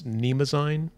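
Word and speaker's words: Mnemosyne: But uh, it looks like it's Nemazine, like you're not supposed to pronounce the M.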